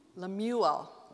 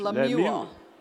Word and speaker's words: Lemuel: Lemuel.